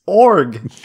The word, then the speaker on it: Org: Orog? – Wrong, you already fucked it up. – Is that how you pronounce it? – It's Org. – Org. – It's like orc, but with a G.